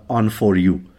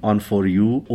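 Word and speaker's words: Onforu: اما این نورافکنی هم که سم راجبش صحبت کرده، یعنی نورافکنی است با عنوان On For You، که حالا البته خب این یه کلمه ساختگیه و به قول سم ما ترجیح میدیم اینطوری تلفظش بکنیم، On For You، O-N-F-O-R حرف U.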